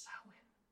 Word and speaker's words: Samhain: In the game Samhain, apparently it's not pronounced Samhain. How is it pronounced? – It's pronounced Samhain.